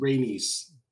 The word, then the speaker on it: Rhaenys: Princess Rhaenys, I don't know how to say her name, or her cousin, Rhaenys. Or her cousin, Rhaenys. – Thank you.